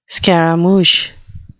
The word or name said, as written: scaramouch